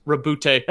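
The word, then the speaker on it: Roboute: Like a quote from Rabuti Gulliman, and it's just, it's pronounced Rabute.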